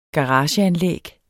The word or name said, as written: garageanlæg